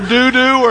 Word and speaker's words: Dodo: You lose any way you pronounce it. It's either doo-doo or dodo. So I would say doo-doo, but I feel like a five-year-old when I say that. So we're going to call this Dodo, okay?